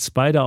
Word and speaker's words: SPDR: es gibt nur einen darauf von S SPDR, heißt Spider ausgesprochen. Der hat 1863 Werte.